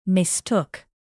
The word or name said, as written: mistook